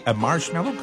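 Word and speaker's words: Marshmello: La preuve que vous êtes au meilleur de la musique, c'est Marshmallow, comme ça on le prononce. – Ouais, tu l'as bien.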